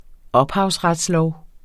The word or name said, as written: ophavsretslov